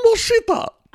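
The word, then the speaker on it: Moschitta: Well, oh, John Moshita.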